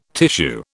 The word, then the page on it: tissue: British English